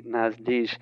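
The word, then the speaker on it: Nasdijj: You know, as I say in my article, that name doesn't actually exist, and neither does the word in Navajo, but he pronounces it Nasdish, as far as I know. – Well, and apparently the writer doesn't actually exist, or at least not the character claiming to be Nasdish.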